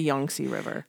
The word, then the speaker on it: Yangtze: It's A's are not pronounced A right. in China. It's ah. – Ah. – Like the Yangtze River. It's not the Yangtze River. It's the Yangtze River.